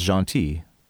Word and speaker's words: gentil: Yeah, it's a great word, but it's because the way the French word is pronounced is gentil, so it's just, sure.